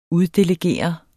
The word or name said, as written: uddelegere